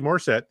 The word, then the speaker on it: Morset: But they can find me at CD Morset, the E is silent.